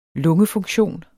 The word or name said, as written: lungefunktion